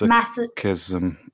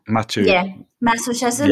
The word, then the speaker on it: masochism: Masochism. Is it? – Masochism. Yep. M-A-S-O-C-H-I-S-M. – Yeah.